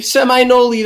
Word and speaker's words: Seminole: Near Seminole, Texas. – Seminole, that's how it's said.